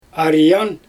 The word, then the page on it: arjan: Xyzyl